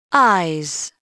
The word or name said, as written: eyes